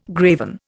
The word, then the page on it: graven: Indian